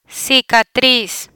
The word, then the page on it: cicatriz: spanish